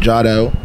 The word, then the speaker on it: Jahdell: He's an artist by the name of Jadell. – Jadell? – Jadell. – How you spell that? – J-A-H-D-E-L-L.